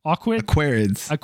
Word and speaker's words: Aquarids: I think it's called the Aqualids meteor shower. Is that it? You're laughing. – I'm sure you pronounce that perfectly. – Pronouncing Aquarid. – Aquarids.